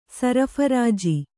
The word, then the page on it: ಸರ್ಫರಾಜು: sarpharāju